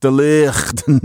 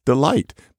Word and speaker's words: delight: Did it used to be pronounced delicht? No, it wasn't. It's just delight.